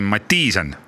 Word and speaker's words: Mattisen: no vot, ma tahtsingi kohe alustuseks selle, selle asja nagu läbi rääkida, et ähm, ma tean, et äh, kahte moodi sinu nime hääldatakse, et äh, hääldatakse nii, nagu kirjutatakse, aga inimesel Eestis on vist ikkagi suupärasem Mattiisen, et kuigi ta ei ole ju Mattiisen kahe i-ga.